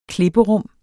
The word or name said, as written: klipperum